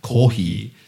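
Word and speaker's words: kohi (コーヒー): But the actual katakana is kohi.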